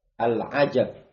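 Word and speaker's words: al-ajab (العجب): Jadi ada al-ajab, ada al-ujub. Ujub itu yang ujub ya, sombong, bangga, sok kepedean, itu namanya ujub. Kalau al-ajab itu kagum. Ya. Di masih harokat di tempat antum. Jadi bacanya al-ajab.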